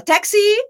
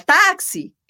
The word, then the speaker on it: táxi: Então, táxi é um excelente exemplo. O americano, o inglês, fala taxi e o brasileiro fala táxi.